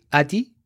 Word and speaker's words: Heidi: Wouldn't it be Adi? – Adi, Adi, Adi. Yes. – like Adi. – because you we, don't, we pronounce don't pronounce the H.